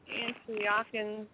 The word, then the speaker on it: Antiochians: Now the Antiochians, is that how you say it? – Yeah, you can say it like that.